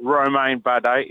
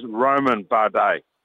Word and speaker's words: Romain Bardet: It is not Romain Bardet. It is Roman Bardet. The I is always silent in a Frenchman's name.